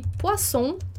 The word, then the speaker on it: poisson: E poisson, que é peixe. Então, aqui é um exemplo de que é muito importante lembrar da pronúncia do S ou dos dois S na palavra, porque muda o sentido, né?